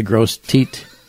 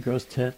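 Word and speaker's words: Grosse Tete: Gross Teat. – Is Gross Teat a gross tet? I have no idea what it's French. – Spelled like. – T-E-T. – Oh, it's probably a tet.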